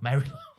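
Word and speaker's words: Mari Lwyd: Mary Lloyd. Shut up, Ross. Mary Lloyd. – Marty Lloyd. Marty Lloyd. – Yeah. – Mar-y-Lloyd. It's spelled like L-W-Y-D, which is like we would say lewd, wouldn't we? – The L is weed.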